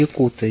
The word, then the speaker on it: якуты: Якуты, не якуты, якуты. Да, это вот правильное произношение, якуты.